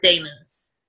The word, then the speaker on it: daemons: Is it demons, or do you pronounce it daemons? – Daemons. – Daemons, okay. So she's got two demons,